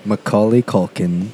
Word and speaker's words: Macaulay Culkin: Is that how you say his name? – Macaulay Culkin. – Macaulay Culkin. Yeah, I'm not his...